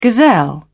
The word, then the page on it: gazelle: British English